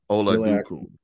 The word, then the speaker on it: Oladokun: And I'm sure I did not pronounce his last name correctly. I apologize. Oladokun. – Exactly.